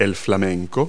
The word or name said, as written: flamingo